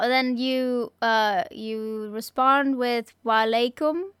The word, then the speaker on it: Wa alaikum: Wa alaikum. – Wa alaikum.